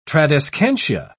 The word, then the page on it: tradescantia: British English